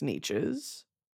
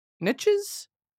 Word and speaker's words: niche: It's said that this mine has many things other than just gems nestled in its niches. Niches? Niches? Nietzsche. How to pronounce niche? Niche. It's not each? – Niche.